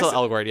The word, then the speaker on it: Elgort: His name is Ansel Elgort? Is that how you say it? – Of course, Elgort, yeah.